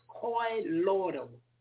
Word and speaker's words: colloidal: Okay, so it's pronounced colloidal, colloidal silver, colloidal silver.